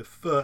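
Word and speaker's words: phở: It's spelled P-H-O, and it's actually pronounced pho.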